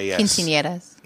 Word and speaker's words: quinceañeras: One thing about my Mexican culture I can tell you about is fucking, how do you say this? Q-U-I-N-C-E-N-E-R-A-S. – Quinceañeras. – Oh, quinceañeras. – Quinceañeras? – Yeah. Quinceañeras. Yeah.